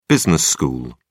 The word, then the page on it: business school: British English